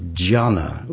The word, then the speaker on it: dhyana: Zen is actually Japan's interpretation of Chan, which is a kind of Chinese pronunciation of jhana, which is a Sanskrit word, which is D-H-Y-A-N-A.